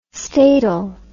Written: statal

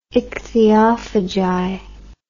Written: ichthyophagi

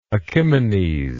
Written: achimenes

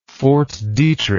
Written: Fort Detrick

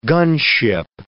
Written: gunship

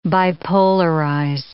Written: bipolarize

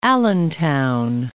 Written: Allentown

